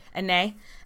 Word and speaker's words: Anet: Yeah, so the Chateau d'Anet, is that how you say it would be? I've been saying Annette. – Annet. – Okay, so... – A-N-E-T. – Yeah. – Google it. – Annet. That sounds right.